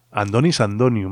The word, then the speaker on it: Antonis Antoniou: Andonis Antoniou, más bien, creo que lo pronuncian así, pero bueno, lo habréis escrito Antonis Antoniou,